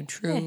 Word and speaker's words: Truus: Two U's. Truus? That sounds good. – Definitely pronounce the second U. Truus.